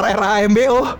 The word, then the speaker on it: Rambo: Rembo. Rembo yang mana anjing? R R A M B O. Rambo. Eh. Rambo. Rembo. Rembo mah. Rembo mah ini yang ayamnya Upin Ipin. Bukan Rembo bacanya.